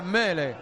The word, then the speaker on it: Mele: Lo he dicho muy bien, ¿eh? – Muy bien. Oh, muy bien, muy bien. – Es así como se pronuncia. Mele.